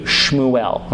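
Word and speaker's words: Shmuel (שמואל): The Hebrew term for this book is, you would think it would be pronounced something like Samuel or something like that, but technically the Hebrew is pronounced Shmuel. So that's how you would say Samuel in Hebrew, Shmuel, okay?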